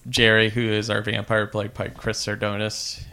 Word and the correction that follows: Sarandon: Chris Sarandon. – Sarandon.